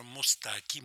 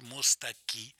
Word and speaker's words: Мустаки: Так вот, мы послушаем песню в исполнении Жоржа Мустаки, Мустаки, греческая фамилия, французский, по-французски она звучит, с ударением на последнем. Слоги «Марш» Сакко и Ванцетти.